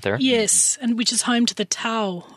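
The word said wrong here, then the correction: Tao: Dau people.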